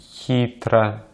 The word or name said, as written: хитро